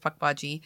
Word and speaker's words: Pukwudgie: But a Pukwudgie, it's spelled Pukwudgie, but it's pronounced Pukwudgie.